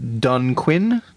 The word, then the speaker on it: Dunquin: By the way, the band's name is pronounced Dunquin. They're named after a village in County Kerry in Ireland.